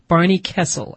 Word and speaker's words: Barney Kessel: Yes, I said it right this time. Barney Kessel. I made the mistake. I said Barney Kessler. I know Barney Kessel. I got it.